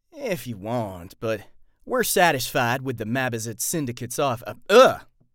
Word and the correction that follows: Mabazot: Mabazit? Is that right? Mabazot. I knew I said it wrong. I'm like, wait a minute, that's not right. Mabazot. Mabazat. well, well, if you want, but we're satisfied with the Mabazat syndicate's offers. All right. Eh, if you want, but we're satisfied with the Mabazot. Mab, how do you say it? Mabazot. So it's all the A's or O's.